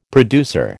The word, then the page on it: producer: American English